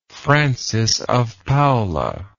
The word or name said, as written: francis of paula